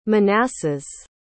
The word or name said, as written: Manasses